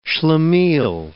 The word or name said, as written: schlemiel